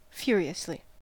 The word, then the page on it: furiously: American English